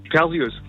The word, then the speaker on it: Perseus: Was sagst du, Perseus oder Perseus? – Perseus. – So, eben. – Wirklich? – Sag ich ja auch. – Mein Leben lang falsch gesagt. – Das hast du das Leben lang falsch gesagt. – Ach.